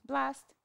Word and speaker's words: Blxst: How do you pronounce this guy's name? – Blast. – Blast? – But you spell it B-L-X-S-T.